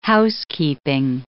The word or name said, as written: housekeeping